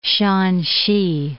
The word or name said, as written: Shaanxi